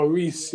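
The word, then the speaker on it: Reus: The guy, I don't know how to pronounce his name properly. The one from Dortmund. Captain Ruse. – Oh, yeah, Reese.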